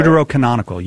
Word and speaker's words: Deuterocanonical: Was the, as you guys call them, Deuterocanonical? I think that's how you say it. – Deuterocanonical, yes. Yes.